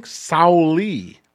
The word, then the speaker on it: Sauli: So, yeah, the Finnish president, I'm going to butcher his name, Sauli Niinistö? – Didn't you learn anything? Sauli. – Sauli.